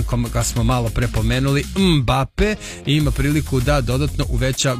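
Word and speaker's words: Mbappé: Ne volim kada kažu Mbappe, brate, on sam sebe ne zove Mbappe, dakle on je Mbappe, pa ako ne umete da izgovorite, nemojte ni da izgovarate.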